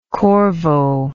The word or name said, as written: corvo